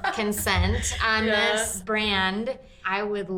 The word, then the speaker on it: Kensent: So it's Consent, but it's spelled K-E-N-S-E-N-T.